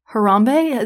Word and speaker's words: Harambe: We are also going to talk about Harambe.